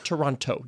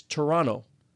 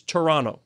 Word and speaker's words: Toronto: Yes, but it's not Toronto. You don't say the second T. They'll look at you and say, you're not from here. – Well, how would you pronounce it? – It's Toronto, like two N's. You don't put the second T. Ask anybody. It's Toronto.